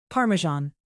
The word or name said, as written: parmesan